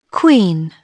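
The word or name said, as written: queen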